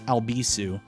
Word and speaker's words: Albisu: You can follow at Alex Albisu there. Last name is spelled A-L-B-I-S-U.